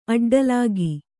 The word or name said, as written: ಅಡ್ಡಲಾಗಿ